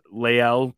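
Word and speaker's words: La'el: And I might butcher his first name, Lael Collins. – Yep, I think you nailed it.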